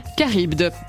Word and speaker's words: Charybde: Charybde, très bien.